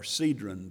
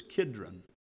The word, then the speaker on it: Kidron: We sang the song earlier about the brook Kidron, or Cedron, depending on how you pronounce it. In the Hebrew, it's Kidron, K-I-D-R-O-N.